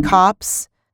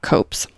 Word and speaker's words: copse: I really, really love the word cops to describe a group of trees. – Cops? – Huh? Cops, that's how it's said? – Yeah. – I've never heard it spoken, so I've always thought copes.